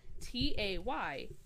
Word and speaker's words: Taylor: My name, Taylor. It is an OR. I know I say it with an ER sound.